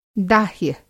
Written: दाह्य

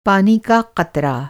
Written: क़तरा

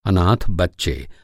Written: अनाथ

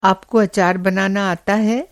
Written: अचार

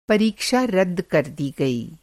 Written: रद्द

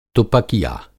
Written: तुपकिया

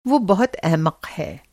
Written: अहमक़

